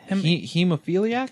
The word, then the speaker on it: hemophiliac: Hemophiliac? – Hemophiliac.